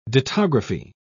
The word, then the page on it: dittography: British English